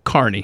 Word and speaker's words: Kearney: Just said that there's one in Kearney, but it's what is he, how do you say it? – Kearney.